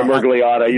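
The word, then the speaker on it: Miragliotta: Dan Mirigliotta, I'm completely mispronouncing that, happened. – Sorry, Mirigliotta, you're fine.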